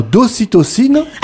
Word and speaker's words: ocytocine: Pourtant, le toucher et les câlins favorisent la libération d'ocytocine.